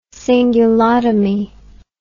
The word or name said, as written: cingulotomy